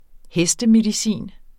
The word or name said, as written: hestemedicin